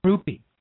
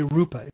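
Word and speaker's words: rupiah: The Iraqi dinar, the Vietnamese dong, and the Indonesian rupee. Now, we don't hear much about, I should say rupa is probably the better pronunciation.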